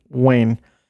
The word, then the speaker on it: Weyn: Specifically troubling were her endeavors to revive the once violent and dangerous ideology of Greater Somalia, or Somali Wayne." That is spelled W-E-Y-N, so I think I pronounced that correctly.